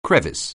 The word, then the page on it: crevice: anglais